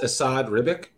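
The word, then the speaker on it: Esad Ribić: Leo, are you talking about the artist for this, Asad Ribic? – Yes.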